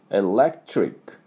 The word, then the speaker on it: electric: エレクトリックっていうふうな発音しなきゃいけないんですけども、これどこがポイントかっていうと、まずエレクト、ここまであの一気にね、エレク、あのきれいに発音、この L ですね、えで、 L と R をしっかり、 electric。で、このトリックなんですけども、 TR なんですね。だからトリックじゃなくて、トリック、T ですぐ R に持っていかなきゃいけないんで、トゥ、これダブルシーン、こういう音になります。トゥ。だから、 electric。